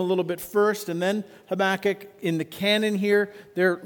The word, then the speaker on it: Habakkuk: You can put the emphasis on a different syllable, but we're going to go with Habakkuk.